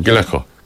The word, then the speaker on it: Gallagher: Und ich finde das eine sehr schöne Überleitung, nämlich A Million Miles Away ist der nächste Song in der Live-Version von Rory Gallagher. Ist das richtig ausgesprochen? – Gallagher.